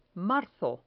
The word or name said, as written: marzo